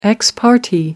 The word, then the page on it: ex parte: American English